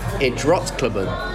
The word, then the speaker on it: Idrottsklubben: Idrottsklubben.